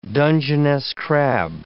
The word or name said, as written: Dungeness crab